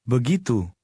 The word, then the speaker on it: begitu: The stress is on the second syllable. – Begitu.